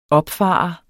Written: opfarende

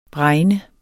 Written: bregne

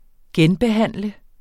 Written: genbehandle

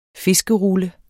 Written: fiskerulle

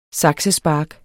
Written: saksespark

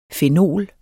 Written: fenol

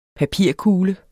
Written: papirkugle

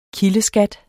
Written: kildeskat

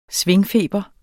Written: svingefeber

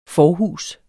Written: forhus

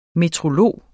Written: meteorolog

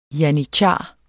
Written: janitshar

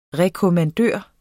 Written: rekommandør

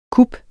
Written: kup

